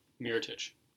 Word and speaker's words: Mirotić: How do you say his name? – Mirotić. So, Nikola Mirotić.